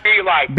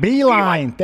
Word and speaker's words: Beilein: John Beilein. – Beilein, thank you.